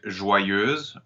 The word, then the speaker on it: joyeuse: For example, with the word joyeuse or the word neutre, the EU is followed by Z or T, and therefore it will be pronounced O slash.